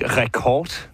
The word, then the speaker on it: Record: Record eller R-record eller R-record? Hvordan det hedder faktisk Record, men det er rigtigt.